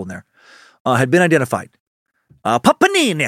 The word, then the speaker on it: Papini: And that family member was related to an ex-boyfriend of Panini's. Oh, that's how you say it. You know what? It keeps reminding me of the fucking sandwich of a panini. It's Papini.